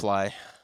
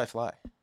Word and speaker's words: Fleay: Tell me, how do I pronounce your name? – Fleay. – You say Fleay. – No, I say Fleay.